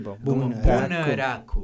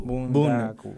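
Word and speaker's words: Bunraku: El Me cuesta pronunciar la, la R. E Porque parece que lo estuviera como parodiando, como el Bunaraku. Me cuesta, mucho. Pues, de hecho, así, así como yo, bueno, no, no soy experto en japonés ni, ni nada, pero eh, por fonética es como se pronuncia por sílaba, ¿no?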